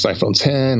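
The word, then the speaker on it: iPhone X: By the way, for the record, I called it iPhone, you know, Roman numeral X, pronounced 10, because OS 10 was always pronounced that way, even though it was an X, but that's another story.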